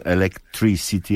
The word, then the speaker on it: Electricity: Electricity, ja specjalnie to tak akcentuję, dobrze, gdyż w nazwie zespołu jest ukryte Electricity, czyli elektryczność, i Three City, czyli Trójmiasto.